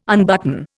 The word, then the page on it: unbutton: Indian